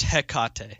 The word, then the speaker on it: Hecate: Okay, It's pronounced Hecate.